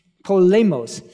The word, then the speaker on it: Polemos: How do you, how do you say that, by the way? I've, I've been bluffing. Okay. Polemos.